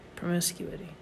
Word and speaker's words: promiscuity: Promiscuity. Promiscuity? Is that mm-hmm. how you? – Promiscuity. – Promiscuous? – Yes, but promiscuity is how you say it.